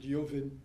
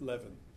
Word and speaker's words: Levin: Okay, so will you hear Lyovin spell it Levin? Not L-E-A-V-E-N, although that would be a neat pun in the case of this character.